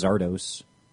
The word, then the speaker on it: Szardos: It's like I just assumed it was Zardos. Because it's spelled S-Z-A-R-D-O-S.